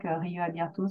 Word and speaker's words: rioabierto: Donc, rioabierto, c'est vraiment comme ça se prononce, c'est de l'espagnol.